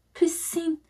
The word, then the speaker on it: piscine: P-I-S-C-I-N-E. Piscine. – Piscine. – That's how I pronounce the word.